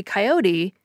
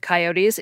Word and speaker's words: coyote: But I believe the three-syllable version is actually the older version, since the word comes to English from the Mexican-Spanish word coyote, which ultimately goes back to the Nahuatl word coyotl.